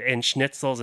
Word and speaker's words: Schnitzel: Before, whether it be like goulash or like tons of sausages and schnitzels and all that stuff, right? – Ooh. – Oh. That's great. – I love them snitch.